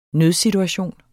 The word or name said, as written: nødsituation